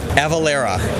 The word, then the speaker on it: Avalara: Avalara. – Avalara.